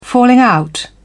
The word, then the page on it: falling out: British English